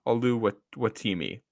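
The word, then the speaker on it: Oluwatimi: Ricky Stromberg from Arkansas, John Gaines from UCLA, and Olawemudi, I think is how you say it, from Michigan. – Yes. Yep. – Oh, that's Olu Watimi. That's what it is.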